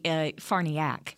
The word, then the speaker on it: Farniok: It's actually Farniak. – Farniak. – It's with an O, Farniok, but it's pronounced Farniak.